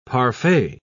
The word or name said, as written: parfait